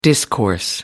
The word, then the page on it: discourse: American English